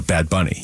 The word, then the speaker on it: Bad Bunny: No, Bad Bunny. You said it right the first time. Bunny. If you put the emphasis on bad, it makes it sound like there's at least one other bunny running around.